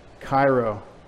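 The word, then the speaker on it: chairo: Cairo, not a city in Egypt. C-H, that first X looking thing is a key. It's a C-H. C-H-A-I-R-O, long O, Cairo.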